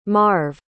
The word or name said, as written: marv